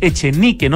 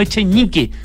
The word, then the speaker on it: Echenique: así que lo pronunciamos con todas sus letras, y es Echenique, no Echenique, ¿cierto, Philippe? – Por supuesto.